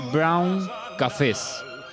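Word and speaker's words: bruin cafés: Eh, Bar Marrón dice, hola, les cuento que los bares tradicionales de Holanda se llaman Brun Cafés, se pronuncia Brown Cafés.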